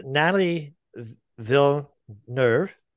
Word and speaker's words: Nathalie Villeneuve: And Nathalie Villeneuve is with us. Did I say that right, Nathalie? – Yes, you did great. Sounds very close to it.